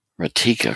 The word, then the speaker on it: Ratika: Ratika. Okay, that's how you pronounce it. The way it was spelled, I wasn't quite sure.